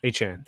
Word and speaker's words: Achane: So, and Mostert, and he may not play, but Devon Achane or Achane or whatever, however you say his name. – Achane.